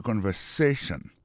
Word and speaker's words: conversation: Mot conversation, accent tombé sur avant-dernier syllabe là. You know? Listen to the conversation.